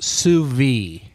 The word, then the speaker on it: sous vide: It's pronounced sous vide. – Right. – Yeah. – It's V-I-D-E.